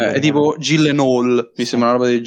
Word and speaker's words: Gyllenhaal: Gillenol.